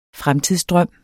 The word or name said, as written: fremtidsdrøm